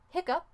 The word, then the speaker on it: hiccough: Tenemos hiccup, H-I-C-C-O-U-G-H, con el sonido up. Normalmente se escribe hiccup con H-I-C-C-U-P, pero también se puede escribirlo con H-I-C-C-O-U-G-H.